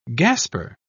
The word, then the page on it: gasper: British English